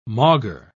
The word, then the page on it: maugre: British English